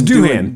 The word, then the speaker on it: Doohan: So like he was talking to Mickey Dugan about riding the 500. – Dugan? – I can't say his name. – It's Doohan. – Doohan, whatever. – Hey, it's Doohan. Mickey Dugan's a British comedian.